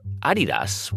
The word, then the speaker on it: Adidas: He's spent nearly 30 years working for Adidas, which is actually how it's pronounced.